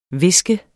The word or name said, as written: hviske